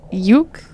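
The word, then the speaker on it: Jocke: Um, they they pronounced it Yuke, but like Jock.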